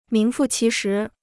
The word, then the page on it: 名副其实: míng fù qí shí